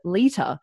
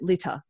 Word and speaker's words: Lita: And then I saw this female on there called Lita, but at the time I pronounced it Litter. I didn't know any better.